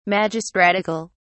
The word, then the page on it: magistratical: British English